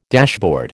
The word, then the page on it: dashboard: American English